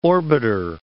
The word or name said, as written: orbiter